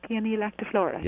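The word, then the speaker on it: Paeonia lactiflora: Peonia lactiflora. – Yeah, Peonia lactiflora.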